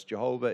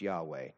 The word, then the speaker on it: Yahweh: Jehovah is the mispronunciation of Yahweh. It's Yahweh.